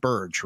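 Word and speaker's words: Birge: It's George Burge, right? That's how you say it? – Mm-hmm. – For the longest time, I kept saying George Berge for some reason. And I had to correct myself. I'm glad I never met him and said it wrong.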